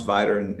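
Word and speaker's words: Vidor: It said, am I pronouncing this right? Vigor? I never heard of that town before. It's just outside of Beaumont? – Yeah, is that right? – There's I was born in Beaumont, and spent my life, you know, in between those two towns, Vider and Beaumont. – Viger. I didn't know how to pronounce it.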